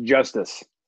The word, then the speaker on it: Justus: Yeah, I think it's pronounced Justice. – Justice, yeah, with an I.